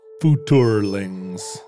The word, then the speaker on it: Futurelings: Well, it would be like Futurlings. – I think it's four syllables. It's futurlings. – Futurlings. – Right, like chitlings.